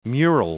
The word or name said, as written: mural